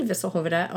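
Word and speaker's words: Visselhövede: I know that's pronounced correctly because I've actually been to Visselhoveda, although not for research.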